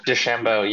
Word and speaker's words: DeChambeau: Um, I had to look up his name because it's kind of weird to pronounce, but uh, he Bryson DeChambeau. Oh, DeChambeau, yeah.